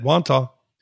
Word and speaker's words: Wantagh: in Wantagh, New York. Did I pronounce that right? – You did, Wantagh.